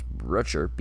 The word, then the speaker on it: Brutcher: Drew Butcher, I want to say his name is Drew Butcher, but no, it's Brutcher, B-R-U-T-C-H-E-R, from South Florida.